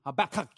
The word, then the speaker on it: Habakkuk: Everybody say Nahum, Habakkuk. Oh, no. Habakkuk. Get it up, right up in there.